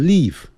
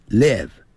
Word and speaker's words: live: I live 就是生活，这个不要念成 live，不是 live，要短的元音 it live。 I live together，不要念成 together，要念 together 那个 T-O-G-E T-H-E-R 有个 R 结尾，所以在美式的发音呢要卷舌的。